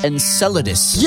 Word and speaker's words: Enceladus: I think it's pronounced Enceladus.